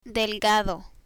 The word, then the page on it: delgado: Spanish